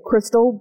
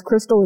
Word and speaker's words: Kristle: You can like or share my business page at Kristle Brooks Insurance. Kristle is spelled K-R-I, S is in Sam, T is in Tom, L-E.